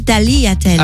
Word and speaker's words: tagliatelles: Non, parce que je vous rappelle qu'on dit tagliatelles.